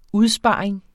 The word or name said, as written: udsparing